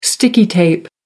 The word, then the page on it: sticky tape: American English